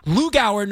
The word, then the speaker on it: Lugauer: So Sergio, I apologize when we meet, I'll apologize to your face, because people see my name and they completely butcher it, because it's not pronounced the way it's spelled. L-U-G-A-U-E-R, Rosenberg, how would you say that? – I'd probably say Lou Gower.